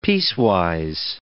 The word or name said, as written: piecewise